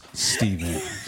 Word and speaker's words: Stephen: I made sure I was an internet thug and typed, it is pronounced Stephen.